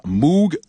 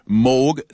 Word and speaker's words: Moog: C'est un compositeur des années 60-70 qui a été un des premiers à utiliser le synthétiseur Moog ou Moog selon la bonne prononciation.